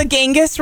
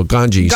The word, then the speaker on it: Ganges: Yeah, there's the Ganges River. – No, Ganges.